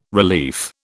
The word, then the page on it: relief: British English